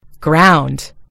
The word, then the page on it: ground: American English